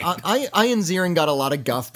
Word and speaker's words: Ian: Oh, and lest we forget, the great Ian Ziering. – No, it's Ian. – Is it Ian? – It's pronounced Ian.